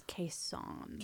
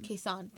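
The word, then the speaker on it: Khe Sanh: Which, that point, it actually, same verse, he really liked verse 4, with this idea of, I'm not sure if anyone's familiar with the Kaesong. I think I'm butchering that. – I believe it's Kaesong. – Kaesong, thank yeah. you. Kaesong, yes.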